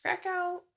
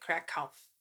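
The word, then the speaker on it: Kraków: So I've heard it pronounced Krakow. That's probably how we would all pronounce it because that's how it looks in English. And then I've also heard that they pronounce it Krakow, like with a V at the end. Kind of sounds like a V.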